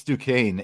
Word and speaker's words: Duquesne: Is it Duquesne? What? Am I saying it wrong? – Yeah, it's Duquesne, and how do you know this? – Because I follow their basketball team.